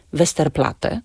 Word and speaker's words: Westerplatte: I ponieważ są to nazwy niemieckiego pochodzenia, a w języku niemieckim nie wymawia się podwójnego t, zatem wymawiamy tylko jedną głoskę. Na przykład w nazwie Westerplatte czy ulica Trauguta.